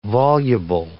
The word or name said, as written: voluble